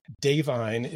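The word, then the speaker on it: Da'Vine: Mary, the actress is Davine, is how it's pronounced.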